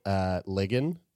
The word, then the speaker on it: Ligon: Zoe Ligon. – Rhymes with friggin'.